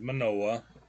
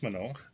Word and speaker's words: Manoah: She has a husband, his name's Manoah. – Good pronunciation on that. – Yes. – I think it's Manoah.